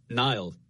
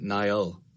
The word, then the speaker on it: Niall: When I was growing up, I would say Niall, like N-I-L-E effectively, like the river.